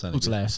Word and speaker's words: Putellas: So, yeah, I think Spain were, despite the fact they have got a number of the world's best players, but Alexia Putellas, never pronounce her last name. – Putellas, that one's Putellas, there we go.